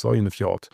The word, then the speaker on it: Sognefjord: Und am nächsten Tag geht es dann weiter durch den ich weiß gar nicht, wie wird es ausgesprochen, der Sognefjord. So spricht es irgendwie der Norweger aus, Sognefjord.